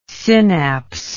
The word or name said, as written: synapse